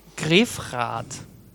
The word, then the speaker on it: Grefrath: Und dann hast du, du bist dort aufgewachsen und in Grefrath.